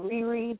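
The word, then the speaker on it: Rere: But anyway, y'all can follow me on Twitter and on Instagram at Houston underscore Riri. That's R-E-R-E.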